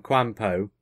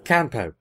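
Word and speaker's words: K'anpo: Yes, if you go back to Planet of the Spiders and you see Choji and Quanpo... Did I say that right? No. Whatever. – Quanpo.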